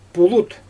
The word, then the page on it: pulut: Xyzyl